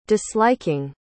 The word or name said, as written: disliking